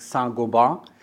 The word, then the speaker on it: Saint-Gobain: I understand the right pronunciation is something like Saint-Gobain.